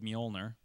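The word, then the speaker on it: Mjölnir: They don't want to give away all the secrets, but somehow Thor is going to lose his power to wield Mjolnir.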